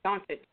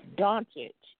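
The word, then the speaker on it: Dončić: It's spelled, it looks like it should say that, but the C is actually sounds like a CH. – Doncic. – It's Doncic, yes.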